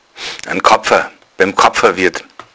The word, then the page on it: Karpfen: bayerischen Dialekt